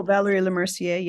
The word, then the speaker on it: Valérie Lemercier: Valérie Lemercier.